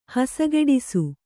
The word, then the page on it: ಹಸಗೆಡಿಸು: hasageḍisu